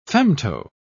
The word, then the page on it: femto-: British English